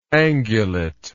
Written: angulate

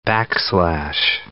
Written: backslash

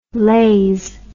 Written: lays

